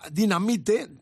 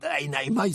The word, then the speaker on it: Dynamite: Vamos a poner el corte 2 de este Dynamite, que así se llama Dinamite, Dynamite se pronuncia, que es el primer disco proyecto del gallego Rubén Cuerdo, junto a músicos portugueses.